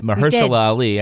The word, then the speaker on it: Mahershala Ali: But I did like the performances of Mahershala Ali and, did I say it right? Mahershala Ali. – You did. Congratulations.